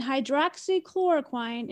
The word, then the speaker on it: hydroxychloroquine: Under treatments on their website, they list ivermectin, which is horse dewormer, and hydroxychloroquine.